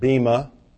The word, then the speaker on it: bema: The Greek word for judgment seat is bema, or we pronounce it bema.